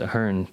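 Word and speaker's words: Aherne: I think it's pronounced Ahern because that's how they say it on the commentary.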